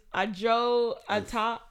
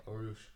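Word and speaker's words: Arooj: Is Ajo Ata off top? – That is not how you say it. GG. – It's A R O O J.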